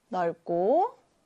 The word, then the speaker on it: 넓고: 그 다음 뒤에 있는 기역이 있는 이 고는요, 고가 아니라 쌍기역이 이렇게 쌍기역으로 소리가 나요. 그래서 넓고라고 읽고요.